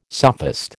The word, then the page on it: sophist: American English